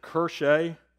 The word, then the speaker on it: Kirche: Church, the English equivalent, is the German word, and I pronounce it Kirche. It's pronounced a little bit different in the German dialect.